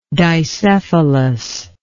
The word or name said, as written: dicephalous